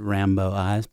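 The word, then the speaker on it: Rimbaud Eyes: That was Dum Dum Girls with Rimbo Eyes. You could possibly pronounce it Rambo Eyes, but I think that would make things confusing if you consider the 80s aesthetic especially.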